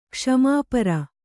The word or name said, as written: ಕ್ಷಮಾಪರ